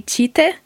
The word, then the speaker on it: Tite: It's now I always want to call him Tite, but that's not how you say it, is it? It's It depends on the region in Brazil. I would say in São Paulo they'd say Tite.